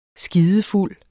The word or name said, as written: skidefuld